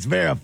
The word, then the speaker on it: Zverev: Only Christian can say Zverev. – That's right. – Like that, Zverev.